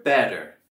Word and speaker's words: better: Y en la palabra better tenemos también el sonido flap T con la B-E-T-T. Alright, con ese doble T vamos a hacer el sonido flap T, donde se hace como un sonido D, D.